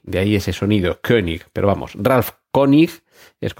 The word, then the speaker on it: König: König, escrito con una O con diéresis, que en alemán, si no estoy equivocado, se pronuncia como una especie de E con la boca en forma de O o algo así.